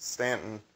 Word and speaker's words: Staunton: So if you like the city of Staunton in Virginia is spelled Staunton, but it's pronounced Staunton. And they purposely did, the story goes, is they purposely did that to be able to tell who was local and who wasn't by the way you pronounce the name of the city.